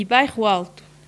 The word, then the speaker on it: Bairro Alto: On dit Baixa Pombalina e Bairro Alto.